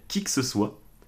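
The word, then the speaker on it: Kick ce soit: et ça s'appelle Qui que ce soit. Euh, mais qui que ce soit, ça s'écrit kick, K-I-C-K, donc comme le kick, ce soit.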